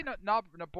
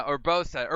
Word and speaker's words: Urbosa: And then, why can I never say her name? Gerudo. – Urbosa. – Urbosa. I always want to say Noboru or Orboro, or like why? I don't know. But no, Urbosa.